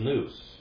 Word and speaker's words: nous: Another word for mind in Greek is nous. Nous. N-O-U-S, that's why you'd spell it in the English, but it's nin, omicron, ypsilon, sigma.